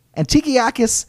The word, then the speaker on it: Antiochus: Antiochus, it's been a while since I tried to pronounce a Greek word.